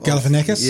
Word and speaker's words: Galifianakis: Galifianakis? – Yes.